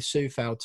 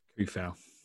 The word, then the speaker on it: Coufal: Just quickly, before I carry on with that tactical soliloquy, how are we pronouncing our new right-back surname? Because I've gone with Kufau already. You've gone with Sufau. I think you're going to have the deciding vote. – Kufau. – Kufau, yeah. You're going with the hard C.